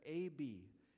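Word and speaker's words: Ab (אב): The father word is the Hebrew word, which is an A-B, and you pronounce it like Ab, and that's where Abba, father, comes from, that we're all familiar with.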